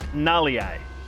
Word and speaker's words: Galeai: Did I get Galea wrong? – It's spelled G-A-L-E-A-I. It's pronounced Nalii.